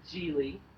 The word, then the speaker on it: Gigli: It's called Geely, but it's spelled G-I-G-L-I.